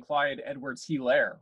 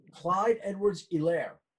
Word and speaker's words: Clyde Edwards-Helaire: Yeah, well, first of all, some applause there for saying Clyde Edwards-Helaire, right? I think we've all kind of been butchering that. That's the correct pronunciation. It's like J.J. Arcega-Whiteside of this year, right? So appreciate you nailing the pronunciation. – Wait, so it's Clyde Edwards-Helaire? Helaire, like Helaire. – Exactly. That's what the pronunciation guide says.